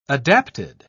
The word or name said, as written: adapted